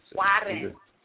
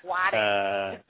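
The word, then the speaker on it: Juárez: Juarez, am I saying that right? Juarez, Mexico, from AFP. I don't know what AFP is. – Juarez. – Is it Juarez? – Juarez.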